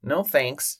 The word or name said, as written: no thanks